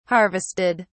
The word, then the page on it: harvested: British English